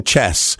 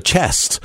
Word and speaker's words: Cześć: Cześć. I'll keep saying it, chest. Bit like playing chess, and that's how you say it. Oh, no, no, it's more like your chest. Your chest, that's what it is.